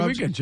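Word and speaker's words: Reagins: We will N-O-T, not interrupt. Next hour, Tony Regans. I said that right? – Tony Regans, yeah.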